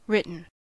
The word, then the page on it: written: American English